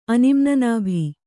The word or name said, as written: ಅನಿಮ್ನನಾಭಿ